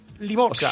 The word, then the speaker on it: Limoges: García, en toda su vida, nunca supo pronunciar Limoges. – Limoges. O sea, ¿cómo yo diría? Limoges. – Limoges. Limoges. Él pronunciaba Limoges como si fuera Portugal en vez de Francia.